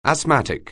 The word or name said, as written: asthmatic